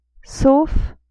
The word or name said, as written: sauf